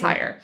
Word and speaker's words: Heyer: And I hope I'm pronouncing that. I think it's spelled H-E-Y-E-R. – I believe you're pronouncing it right. – I think it's higher.